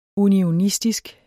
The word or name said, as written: unionistisk